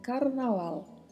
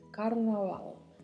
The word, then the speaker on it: carnaval: A gente tem um R com vibrato, um V com leve som de B e a letra L ao final que é pronunciada com o som gerado tocando a ponta da língua na parte superior da boca. Então, carnaval, carnaval é a maneira correta de falar.